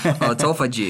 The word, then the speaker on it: autophagy: So what is autophagy? Phagy? Autophagy? Is that how you say it? – Autophagy. – Autophagy, okay.